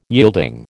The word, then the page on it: yielding: American English